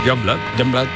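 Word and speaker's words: Yungblud: Youngblood.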